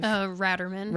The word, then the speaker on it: Ratterman: Their names are Nate and Kaylee Raderman, I believe. – Ratterman. – Ratterman. – I just heard them say it in an interview because okay. I was saying Raderman too. It's spelled like Rate.